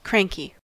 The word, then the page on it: cranky: American English